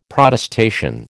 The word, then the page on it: Protestation: American English